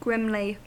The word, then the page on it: grimly: American English